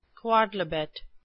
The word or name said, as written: quodlibet